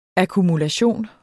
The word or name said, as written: akkumulation